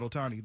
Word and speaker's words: Ohtani: If you saw that apology that he gave a couple days ago uh, about Ohtani, then you am I saying that right? Yeah. Yes. – Shohei Ohtani. Yes, Just making sure.